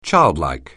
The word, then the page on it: childlike: English